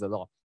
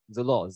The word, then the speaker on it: Chzelor: But even as I actually introduce myself, I tell you guys my name is Zalor. Some of you may be thinking, how am I supposed to spell to pronounce this name? Zalor, Zalor, Zalor. It's actually spelled as C-H-Z-E-L-O-R.